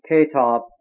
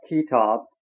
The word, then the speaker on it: Kitab: Kitab is pronounced Ketab, not Kitab, and means the book.